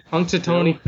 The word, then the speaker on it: Punxsutawney: Punxsutawney? – Punxsutawney Phil.